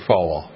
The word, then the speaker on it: Falwell: Falwell. – Jerry Falwell.